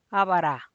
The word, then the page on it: a bara: Teotitlán del Valle Zapotec